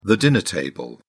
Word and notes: dinner table: British English

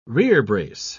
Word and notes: rerebrace: British English

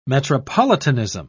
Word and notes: metropolitanism: British English

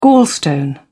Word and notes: gallstone: British English

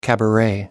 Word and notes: cabaret: American English

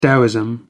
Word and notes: taoism: American English

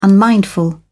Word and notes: unmindful: British English

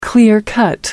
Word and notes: clear cut: American English

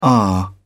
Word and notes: aah: British English

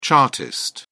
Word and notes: chartist: British English

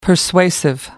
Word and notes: persuasive: American English

